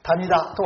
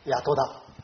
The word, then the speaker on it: 谷戸田: 谷田とは読まない、谷戸田。